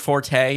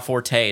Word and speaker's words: forte: That's initially was incorrect, but since so many people used it, it became correct nomenclature. The original pronunciation is just fort. – Oh, I'm sure there's a lot of that stuff. – But now forte is acceptable.